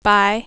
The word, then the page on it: buy: American English